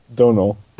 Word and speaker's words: Domhnall: Domhnall. – Donal. With a D. – It's like Donald, but without the Donald part. Donal.